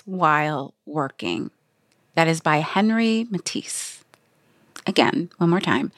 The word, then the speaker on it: Henri: He is French. So it is a French name, and you need to pronounce it Frenchly. – Henri Matisse. – Henri.